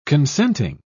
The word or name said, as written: consenting